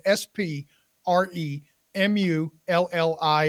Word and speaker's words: Spremulli: It's my name, and Spremulli is spelled S P R E M U L L I, and it's in the show notes, and you can find it on LinkedIn.